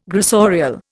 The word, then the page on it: gressorial: Indian